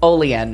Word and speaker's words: Olean: Now I think they pronounce it Olean, New York.